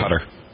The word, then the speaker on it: Qatar: I always wonder, Qatar, is that how you pronounce Qatar. – It? You Qatar? – Yeah. – Why'd they spell it that way? C-U-T-T-E-R instead of Q.